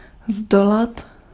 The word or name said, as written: maîtriser